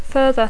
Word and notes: further: English

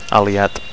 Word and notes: aliat: Catalan

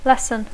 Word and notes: lesson: English

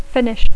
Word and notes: finish: English

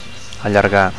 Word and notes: allargar: Catalan

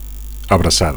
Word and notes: abracada: Catalan

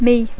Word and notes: me: English

